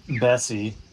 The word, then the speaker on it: Bessie: Yeah, you know, yesterday in Florence at the restaurant for lunch, which was a fantastic lunch at Brandolino, Bessie, as he said, rhymes with messy, and almost the same ability on foot skills in soccer.